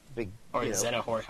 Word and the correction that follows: Xehanort: How do you pronounce it? – Xehanort. – What? – Xehanort. – Okay.